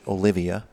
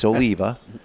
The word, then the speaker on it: Oliva: I don't like when people mispronounce it and pronounce it Olivia. – No. – It's Oliva.